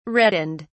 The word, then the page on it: reddened: British English